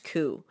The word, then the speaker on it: coup: First of all, if you aren't familiar with the term coup, the P is silent. It is pronounced coup.